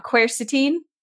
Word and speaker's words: quercetin: I was saying it wrong. Quercetin.